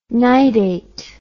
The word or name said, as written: nidate